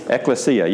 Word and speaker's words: ecclesia: So it's pronounced ecclesia, so everybody say it together. Ecclesia, good. And what is it? Does anybody know Spanish? Anybody know Spanish? – No. – Yeah, it sounds like iglesia, right?